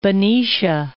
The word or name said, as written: Benicia